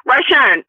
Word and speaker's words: Rhonesha: Okay, or let's say Rhonesha. I said that correctly? – Rhonesha, if you lent me money, right? You right. say, okay, I come to you. I say, Rhonesha, can I borrow, you know, $100 until next month and I'm going to pay you back?